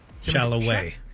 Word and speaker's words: Chalamet: How do you pronounce his name? – Chalamet.